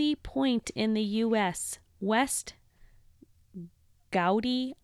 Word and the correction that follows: Quoddy: Head. I'm not sure how to say that. I'm sorry. – You probably nailed the Hawaiian pronunciation. – Gowdy, maybe? It's Q-U-O-D-D-Y. West. – It's probably like Quoddy. – West Quoddy Head.